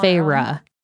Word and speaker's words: Feyre: Anyway, so the scene in ACOTAR where she, Feyre, is doing the test, like the maze trial thing for the fairy queen. – It is pronounced. – And she's doing it all on Feyre.